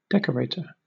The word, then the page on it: decorator: British English